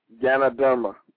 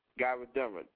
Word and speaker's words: Ganoderma: Ganoderma. – Ganoderma, thank you.